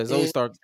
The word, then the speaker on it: Zoe Stark: Zoe Stark. Did I say it right? – Yeah, Zoe Stark.